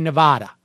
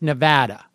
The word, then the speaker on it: Nevada: So whenever I see anything that is like my go-to, even if it's A-R, is ah. So I say Nevada. – Dude. – But apparently it's Nevada.